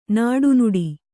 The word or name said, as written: ನಡನಡ